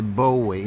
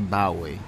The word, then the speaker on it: Bowie: Okay, men och notera att han heter Bowie, inte Bowie.